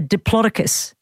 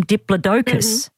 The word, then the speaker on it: Diplodocus: One of those dinosaurs, when I was growing up, it was called a Diplodocus, but now they call them Diplodocus. Mm-hmm. – Yeah, I've saw that name, yes. – Oh, it's just strange because the emphasis is on the wrong syllable for me there because I've always known it as a Diplodocus.